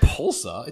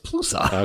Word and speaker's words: Palooza: It's not Pulsa. It's Palooza.